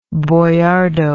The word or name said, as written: Bojardo